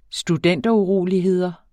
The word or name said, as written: studenteruroligheder